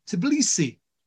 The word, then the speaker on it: Tbilisi: TBC Bank Group PLC is a prominent financial institution headquartered in Tbilisi, Georgia.